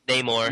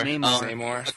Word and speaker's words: Namor: Namor. – Namor. – According to the one, someone posted the thing about the Venn diagram of who owns who in the Marvel movie universes. Uh-huh. And Namor was somehow off on his own,